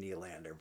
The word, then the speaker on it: Nylander: I don't think we're prying him away, but I'm trying to think of people who maybe aren't forever, like better than William Nylander, probably.